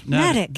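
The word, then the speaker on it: Neddick: It rhymes with medic, according to this. – Nedick.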